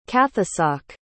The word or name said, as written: cathasach